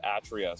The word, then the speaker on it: Atria: It's actually pronounced Atria. So we've just been running around teaching people how to say a word wrong for a long time.